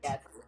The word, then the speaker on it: Gatsby: But Gat. Gatsby. Like Gat. Like bus a Gat.